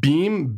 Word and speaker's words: Biem: It's spelled Beam, B-I-E-M.